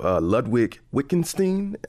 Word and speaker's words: Wittgenstein: but you reference the philosophical teachings of Ludwig Wittgenstein.